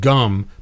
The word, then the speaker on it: Ghum: The band is called Gum, but it's spelled G-H-U-M.